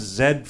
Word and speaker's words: Z: Yeah, I believe it's pronounced Zed for Zamboni.